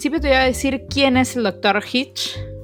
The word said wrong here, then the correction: Hicks: Hicks. Thomas Hicks. Hicks. Gracias por esas correcciones en la pronunciación en inglés, que para mí es fatal. – Sí, no es Hitch con CH, es Hicks. O sea, H-I-C-K-S.